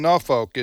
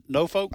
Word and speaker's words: Norfolk: And that's how you pronounce it, Norfolk? – I don't, Norfolk. They kind of just leave off. They only use a few of the letters.